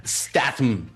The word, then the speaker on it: Statham: Ahí lo puse en los comentarios. Se pronuncia Statham.